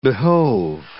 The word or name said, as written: behove